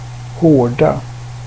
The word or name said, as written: hårda